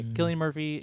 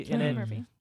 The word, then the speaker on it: Cillian Murphy: Murphy, is that how you say his name correctly? – Yes. – Yes. Okay. Cillian Murphy. – Cillian Murphy.